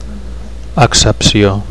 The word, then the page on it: accepcio: Catalan